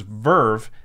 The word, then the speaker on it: VRV: This episode is actually brought to you by VRV, which is pronounced Verve.